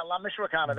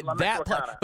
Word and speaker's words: La Michoacana: but La Michoacana on summer. – Oh, yeah, La Michoacana. That's La Michoacana.